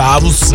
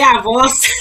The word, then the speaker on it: avos: E outra coisa, 16 avós, tá aqui, avos ou avós? – Avos. – Eu falei certo, né? Porque falou, tá corrigido, eu achei que eu tinha falado errado. Eu falei avos.